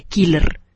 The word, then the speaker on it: Keeler: Algunas divisiones, por ejemplo la división de Keeler, que se escribe K-E-E-L-E-R,